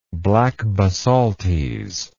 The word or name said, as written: black basaltes